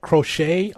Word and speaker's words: Crochet: So the Sox take, with the 11th pick, Garrett Crochet. I think I'm saying that right. Am I Tyler? – I think you're right, yeah.